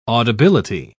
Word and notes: audibility: British English

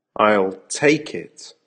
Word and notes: I'll take it: American English

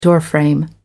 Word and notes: doorframe: American English

